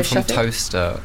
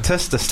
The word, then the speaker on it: Towcester: Toaster. – Toaster? – Near Northampton. – Are you making this up? – No, it's spelled like Towcester. – Towcester, like Worcester. – Yeah, Worcester, Leicester, Gloucester. – Toaster.